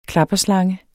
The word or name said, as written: klapperslange